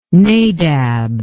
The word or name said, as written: Nadab